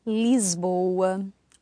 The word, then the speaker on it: Lisboa: So rule number four is, if you have an S at the end of a syllable before a voiced consonant, it'll sound like a Z. And so a couple of examples here, would be words like? – Lisboa, Osvaldo, mesmo, desde.